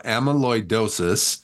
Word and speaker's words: amyloidosis: amyloidosis.